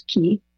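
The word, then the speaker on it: ki (気): That's Chinese, but the Japanese pronunciation of the same character is ki.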